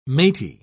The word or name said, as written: matey